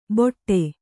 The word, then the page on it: ಬೊಟ್ಟೆ: Kannada